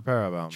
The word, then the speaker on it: Parabellum: Parabellum? – Parabellum. – I'm sorry if I butchered that. – No, that's the proper Parabellum.